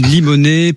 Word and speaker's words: Limonest: Je l'ai écorché tout à l'heure le nom de votre commune, Limonest, près de Lyon.